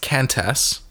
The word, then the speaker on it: Qantas: Yeah, which should be pronounced Qantas. There's no U in it, but anyway.